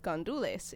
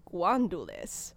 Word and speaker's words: gandules: Uh, one specific overlap is these little beans that are called gandules in Puerto Rico. Ooh. But in Colombia, they're pronounced guandules.